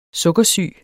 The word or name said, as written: sukkersyg